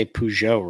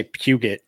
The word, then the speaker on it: Peugeot: Hey, at least I say Peugeot, right? You know, I mean, I didn't say Puget.